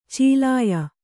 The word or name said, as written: ಚೀಲಯ